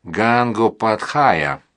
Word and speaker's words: Гангопадхая: Так колонизаторы произносили бенгальскую фамилию, изначально звучавшую как «Гангопадхая».